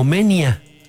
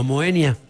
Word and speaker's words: Moenia: Aunque popularmente se lee como se escribe, en latín clásico el diptongo se pronuncia de forma aproximada como Menia, no Moenia.